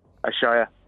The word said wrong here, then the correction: Asahi: I'd pronounce that Asahi, but okay.